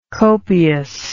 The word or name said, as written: copiosity